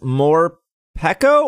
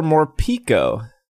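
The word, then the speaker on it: Morpeko: How do we say this? More Pekko or more Pico? – More. I always say more Pekko because of Peckish, but yeah. – That doesn't have any basis in reality.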